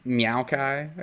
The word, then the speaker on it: Maokai: I gotta go over Maokai. Ah, no, you gotta say it right. – Meowkai.